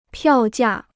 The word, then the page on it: 票价: piào jià